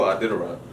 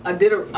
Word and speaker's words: Iditarod: No, Iditarod. – Iditarod.